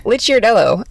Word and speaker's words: Licciardello: How do I say your last name, by the way? – Licciardello. L-I-C-C. It makes the ch.